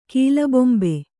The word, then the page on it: ಕೀಲುಬೊಂಬೆ: kīlu bombe